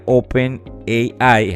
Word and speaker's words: OpenAI: compañía OpenAI, es así como se pronuncia en inglés, que es a su vez quien inventó la herramienta que muchos quizás de ustedes también utilizan, que se llama ChatGPT.